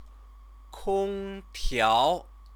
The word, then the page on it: kongtiao: chinese